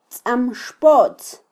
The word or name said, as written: tzamxpotz